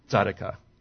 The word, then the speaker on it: tzedakah: Again, we can agree to a pronunciation, which I pronounce as tzedakah.